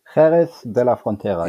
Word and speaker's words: Jerez de la Frontera: Der Kollege war nämlich natürlich mit dem VfL in Jerez, wenn ich das richtig ausgesprochen habe. – Jerez de la Frontera.